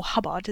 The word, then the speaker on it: Hobart: Well, Henrietta Howard, later Countess of Suffolk, was born in 1689 to Sir Henry and Elizabeth Hobart, or Hubbard, as it's pronounced.